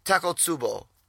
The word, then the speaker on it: Takotsubo: Takotsubo? – Cardiomyopathy. – There we go. You know, I'm special. – Takotsubo.